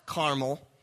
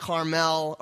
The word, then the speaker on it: caramel: We've got caramel, which is cooked sugar or chewy tan candy, and then we've got Mount Carmel. All right, so we've got caramel and caramel, and it can be pronounced Carmel or Carmel. So I might pronounce it both ways just because I like variety.